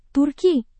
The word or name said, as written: turki